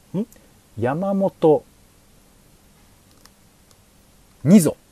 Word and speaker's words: 二三: これ二三と書いて二蔵って読むんですけど、ひふみの一じゃないやつですね。